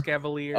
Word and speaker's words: Escavalier: How do you say it? – E-S-C-A-V-I-L-I-E-R. – Escavalier.